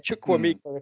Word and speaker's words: Chukwuemeka: It's like, here comes Chukwuemeka. I can't say it, but yeah, it's Chukwuemeka if you actually have to pronounce it.